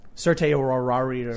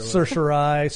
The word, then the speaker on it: certiorari: Certiorari. – Certiorari.